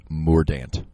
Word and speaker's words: Mordante: I believe that's pronounced Mordant. – Mordant? He won. – M-O-R-D-A-N-T-E.